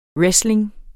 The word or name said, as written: wrestling